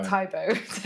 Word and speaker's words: Tae Bo: Well, I, I, even said it wrong. My Tae Bo video. Tae Bo. Taibo. Tae Bo.